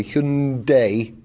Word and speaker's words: Hyundai: Sorry, Hyundai. Rhymes with Monday.